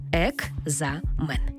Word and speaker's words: екзамен: Слово «екзамен» пишемо з літерою «К». «Екзамен». А як ми його вимовляємо? «Екзамен». Наче чується звук «Г».